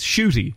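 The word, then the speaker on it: Ncuti: It's spelt with an N and a G, but it's Shuti. That's how it's pronounced.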